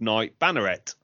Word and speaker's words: Knight Banneret: So, yeah, there is a champion upgrade called Knight Banneret. I believe I'm pronouncing that right. – I think so.